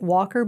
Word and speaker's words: Wacker: And then also my great-grandparents also had, they pronounced it Walker, but it was spelt Wacker.